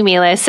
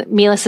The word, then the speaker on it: Meehleis: So you have to spell out my full name, which is Lindsey Meehleis, and Meehleis is spelled funny.